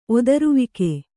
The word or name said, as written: ಒದರುವಿಕೆ